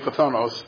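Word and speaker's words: Pathonos (φθόνος): The accent is here. Okay, Pathonos. Okay, yes. Okay, I'm serious. That's how the word is spelled. This is one Greek letter, phi, and this is another one, a theta.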